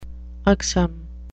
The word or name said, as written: ugsome